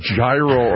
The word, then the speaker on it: gyro: Would you have thought that the G would be silent? Gyro.